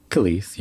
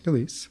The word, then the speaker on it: Kelis: Kelis? How do I pronounce that? – Kelis, yeah. – Kelis.